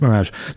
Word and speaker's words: Mi3raj: so it looks like M-I-3-R-A-J, but apparently it's pronounced Mirage.